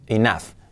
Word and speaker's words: enough: cuidado la pronunciación es enough. Do they have enough money? Y no es money, es money. Money, money, money.